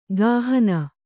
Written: ಕುಹನ

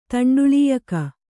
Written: ತಂಡುಳೀಯಕ

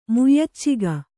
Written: ಮುಯ್ಯಚ್ಚಿಗ

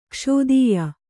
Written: ಕೌಸೀದ್ಯ

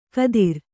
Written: ಖಾದಿರ

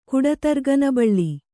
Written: ಕುಡತರ್ಗನಬಳ್ಳಿ

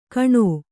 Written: ಕಣೋ

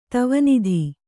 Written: ಧ್ವನಿತ

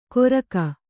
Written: ಗೊರಗ

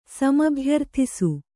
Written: ಸಮಭ್ಯರ್ಥಿಸು

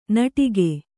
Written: ನಟಿಕೆ

